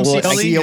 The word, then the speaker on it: Scioli: Tom Scioli is how we pronounce it.